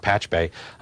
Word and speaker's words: Pachube: which is spelled P-A-C-H-U-B-E, but pronounced PatchBay, which is a site specifically designed for connecting all these different devices into kind of a really big Internet of Things, right?